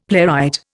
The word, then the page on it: playwright: Indian